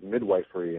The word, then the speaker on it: midwifery: midwifery in Hawaii. How do you say that, midwifery? – Midwifery, yes.